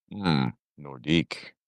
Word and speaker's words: Nordique: And Nordique, if I'm pronouncing that correctly, is French for north. – Nordique. Yeah, I like that.